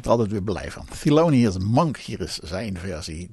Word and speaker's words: Thelonious: De meester zelf, Thelonious Monk. Moet je zo heel goed uitspreken. Thelonious, dat. Ja, dat.